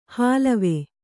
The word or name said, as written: ಹಾಲವೆ